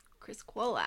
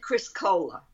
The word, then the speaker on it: Criscuola: You got it pretty close. Peter Criscuola. – Criscuola. – Criscuola. – But everybody said Criscola.